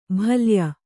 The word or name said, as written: ಬಾಲೇಯ